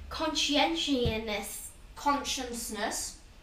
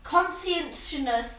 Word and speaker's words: Conscientiousness: Conscientiousness. That is not how you say it. – Conscientiousness.